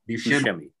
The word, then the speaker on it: Buscemi: How do you pronounce it? Buscemi? Is that right? – Buscemi.